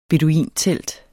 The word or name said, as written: beduintelt